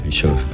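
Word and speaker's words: Verisure: Very pero sure. Very Sure, ay, que me lo dice, eso es. Bueno. – Very Sure. Ahí lo digo bien.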